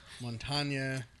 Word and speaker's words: Montagna: But is Montagna, is that Italian or is that Spanish? Montana sounds... – It's spelled with the GN, making it the Italian spelling. – Right. – Montagna, you know, lasagna, that sound.